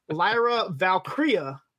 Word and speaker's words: Lyra Valkyria: And then he says, Lyra Valkyria. Did I say that right? And Stacks is like, yeah, pretty close.